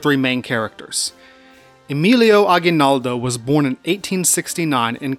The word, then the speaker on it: Emilio Aguinaldo: Emilio Aguinaldo y Fami, often mispronounced as Emilio Aguinaldo, that's the American pronunciation, it's Emilio Aguinaldo, would be the face of Filipino resistance to both the Spanish and the Americans.